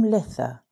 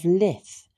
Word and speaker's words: Lithe: Tolkien's Lord of the Rings, the word does appear as the hobbits celebrated their Midsummer's Day by the name of Lith, which is spelled L-I-T-H-E.